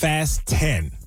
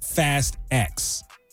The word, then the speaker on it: Fast X: It is pronounced Fast 10, not Fast X. The director wants everybody to know that because it's actually a pun, he says.